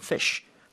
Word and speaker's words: phish: 그게 이제 변형돼서 P-H-I-S-H. 발음은 어차피 P-H-N-F F처럼 fish 하니까 그러니까 일부러 그런 거 아닐까요?